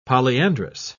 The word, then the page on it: polyandrous: British English